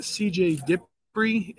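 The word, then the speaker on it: CJ Dippre: C.J. Dipri is how you pronounce his name.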